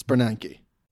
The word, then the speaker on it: Bernanke: Every time you say Bernanke. – Bernanke? – It's Bernanke. – Isn't it like February where the R is silent? – I don't think so.